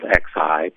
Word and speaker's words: Xi: His name is spelled X-I, pronounced Xi.